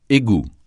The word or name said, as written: égout